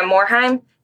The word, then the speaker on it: Morhaime: Yeah, Morhaime.